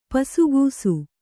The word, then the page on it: ಪಸುಗೂಸು: pasugūsu